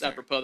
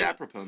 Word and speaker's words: apropos: I think it's apropos that we ask something from David. – What's apropos mean? Is that a Native American tribe? – It rhymes with apple poet.